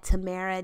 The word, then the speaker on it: Tamara: I am at Tamara J Renee. If you do not know how to Spill my name, because some people spell it wrong, it is T A M A R A J R E N E E.